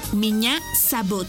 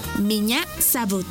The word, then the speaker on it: меня зовут: Y se escribe P-R-I-V-I-E-T. Привет. ¡Muy bien! Repite conmigo. Привет. ¡Muy bien! En ruso, me llamo se dice меня зовут. ¿Y tu nombre? Una vez más. Меня зовут. Por ejemplo, меня зовут. Esta palabra se escribe меня. M-I-Ñ-A. Меня. Зовут. Z-A-V-U-T.